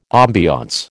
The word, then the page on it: ambience: American English